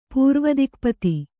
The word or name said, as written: ಪೂರ್ವದಿಕ್ಪತಿ